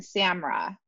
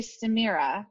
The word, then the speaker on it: Samira: I introduce you to Samira Mbotzafy, M-B-O-T-I-Z-A-F-Y. And I heard her name pronounced Samira and Samra, but I'm going to say Samira because when her really good friend was talking about her during, you know, an interview, that was how it was pronounced.